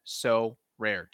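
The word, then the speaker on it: Sorare: It's called So Rare, 2 syllables.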